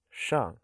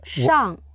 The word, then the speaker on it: 上: Shang. – Yes, Shang.